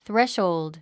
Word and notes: threshold: θréʃhould; スレッショーゥド

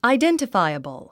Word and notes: identifiable: aidèntəfǽiəbl; アイデンティファイエボォ

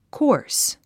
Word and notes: coarse: kɔ’ː r s; コォース

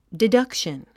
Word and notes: deduction: didʌ́kʃən; ディダクシャン

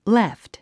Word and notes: left: léft; レェフト

